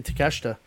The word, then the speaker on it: Takeshita: Let's start with Kenny Omega versus I said it wrong for 2 weeks in a row. I'm just going to say Takeshita.